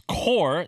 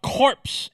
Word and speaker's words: Corps: And for the uneducated, it's core, not corpse, even though it's spelled C-O-R-P-S.